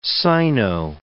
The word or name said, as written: Sino-